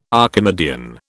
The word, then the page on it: Archimedean: British English